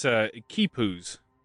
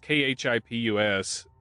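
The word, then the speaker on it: quipus: Yeah, it is Kipus. – Kipus, okay, there you go. – It's spelled weird. It's Kipus.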